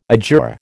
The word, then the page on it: adjure: American English